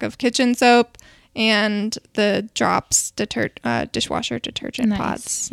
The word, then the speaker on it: Dropps: And drops is spelled DROPPS.